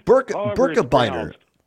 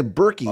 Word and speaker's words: Birkebeiner: Hey, by the way, I wanted to ask you, is that, how do you pronounce it, the Birkebeiner, the Birkebeiner? – Birkebeiner, yeah. I assume it's Swedish. Or Norwegian, pardon me. Norwegian. – Okay. Yeah. Oh, okay. – So, yeah, Birkebeiner. Some people say Berkey. Oh. I thought it was Birkebeiner, but I think most people here pronounce it Birkebeiner.